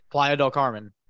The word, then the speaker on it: Playa del Carmen: Is there Playa, del, Playa del Carmen. Oh, that's how you say it.